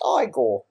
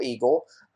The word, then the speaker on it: Igor: It's pronounced Igor, not Eagle.